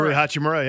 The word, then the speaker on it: Hachimura: Hachimura. – Rui Hachimura, yeah.